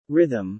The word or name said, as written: rhythm